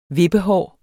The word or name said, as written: vippehår